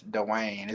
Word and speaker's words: DWANYE: Now, some people say DWANYE, but I promise it's DWANYE.